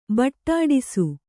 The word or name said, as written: ಬೊಟ್ಟಿಡಿಸು